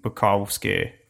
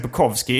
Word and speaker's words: Bukowski: Då ska det utas på, jag säger Bukowski innan. Jag säger nu, egentligen Bukowski, Bukowski men är, jag har av någon anledning nästan på uh, ditt maner börjat ja. uttala det amerikanskt. Korrekt, eller ja. som han säger själv.